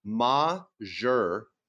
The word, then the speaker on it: majeure: Okay, Joe, a Navy helicopter pilot, says the proper way to say it is majeure. That's M-A-H-Jeure.